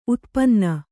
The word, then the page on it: ಉತ್ಪನ್ನ: utpanna